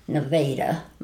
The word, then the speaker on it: Nevada: My dad was born in Nevada, or they pronounced it Nevada, Missouri.